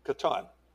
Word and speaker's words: Catan: Catan.